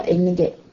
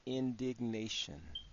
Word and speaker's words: indignation: and indignation. How do you pronounce that? – Very good. Indignation. – Indignation. – Like indignation. Somebody who's indignant, think about digging, okay? In the last part, nation, indignation, okay? – I know what it means.